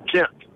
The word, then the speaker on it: Jent: Gent. J-E-N-T.